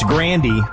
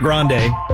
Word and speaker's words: Grande: She says that we actually pronounce her last name wrong this whole time. It's Grandy, not Grande.